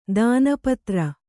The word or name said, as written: ದಾನಪತ್ರ